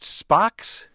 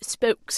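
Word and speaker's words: Spox: Is it pronounced Spocks? – It is pronounced Spokes. – Spokes. – Yes, which it sounds sort of like the wonderful Star Trek character.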